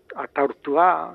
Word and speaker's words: Ategortúa: o Ategortúa, hoy en día se pronuncia como Atortúa.